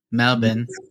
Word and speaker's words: Melbourne: There's one in Melbourne. Melbourne. – Let's go. – That's how they say it.